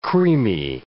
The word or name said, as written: creamy